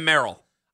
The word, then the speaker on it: Merrill: And how have we gone 10 minutes and not mentioned Sam Merrill. I call Morrell. You can't even say his name right.